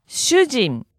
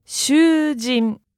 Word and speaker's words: 主人: 主人. 主人. – You hear the long vowel on the second one? One more time. – 主人.